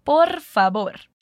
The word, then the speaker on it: favor: A gente só deve cuidar mesmo com a pronúncia do V, que, mais uma vez, falamos com o som de B no espanhol. Então, repete comigo. Por favor.